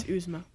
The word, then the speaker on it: Yzma: It's Yzma.